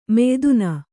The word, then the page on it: ಮಯ್ದುನ: mayduna